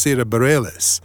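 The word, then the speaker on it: Sara Bareilles: Now, I know it's pronounced Sarah Bareilles, but if I was just to look at her name without knowing that, boy, would I be all over the place trying to pronounce it.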